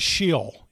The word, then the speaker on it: Sheol: So the Old Testament understanding of hell, the Old Testament word for hell is Sheol. You might have heard that word, pronounced S-H-E-O-L.